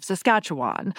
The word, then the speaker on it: Saskatchewan: Gone rhymes with her pronunciation of Saskatchewan, which Johnson says is a just fine way to say it.